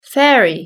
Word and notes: fairy: British English

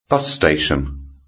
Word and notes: bus station: British English

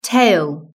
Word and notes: tail: British English

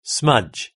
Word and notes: smudge: British English

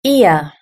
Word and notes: ear: British English

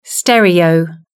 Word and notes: stereo: British English

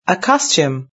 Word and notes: a costume: British English

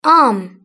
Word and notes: arm: British English

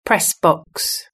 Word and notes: press box: British English

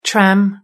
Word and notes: tram: British English

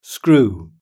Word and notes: screw: British English

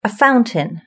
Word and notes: a fountain: British English

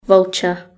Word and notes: vulture: British English

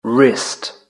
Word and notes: wrist: British English

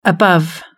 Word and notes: above: British English

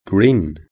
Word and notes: green: British English

